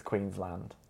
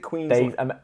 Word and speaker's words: Queensland: And lastly, the anonymous Goonhausen says, the way that pronounces Queensland cracks me up. – It's Queensland. – Is it Queensland? – Australians say Queen. I'm from Queensland. – And I was saying Queensland.